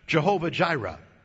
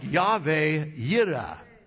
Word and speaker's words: Yahweh Yireh: Now, you have probably heard the expression Jehovah Jireh, right? Well, of course, that's not really how you pronounce it. It is more like Yahweh Yireh.